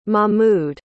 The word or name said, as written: mahmood